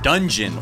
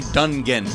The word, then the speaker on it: Dungen: I just started bumping in my eardrums, called Dungeon, or maybe Dungen, but it's spelled D-U-N-G-E-N.